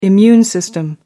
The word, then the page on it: immune system: American English